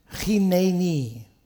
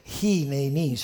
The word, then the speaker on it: hineni: The Hebrew word is chineni. Chineni. So I listened to some preachers, how they pronounce it, and scholars, and in English they can't put that in there, so they go hineni. So if you say hineni, it's fine. God knows what you mean.